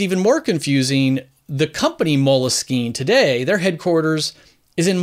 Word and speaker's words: Moleskine: So it was a French word, Moleskine, which is why I pronounce it Moleskine.